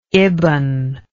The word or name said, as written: ibn